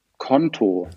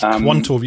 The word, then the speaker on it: Quanto: Also es ist so, tatsächlich Quanto wird ausgesprochen Konto, also wie einfach wie das Konto. – Also nicht Quanto, wie ich es gerade gesagt habe.